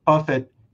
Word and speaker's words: Buffett: So he was referring to Warren Buffett. The correct pronunciation is Warren Buffet.